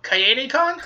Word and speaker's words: Kaede: I'm probably going to butcher the name, but Kaede-Con? – Kaede, yeah. – Yes, I got it right.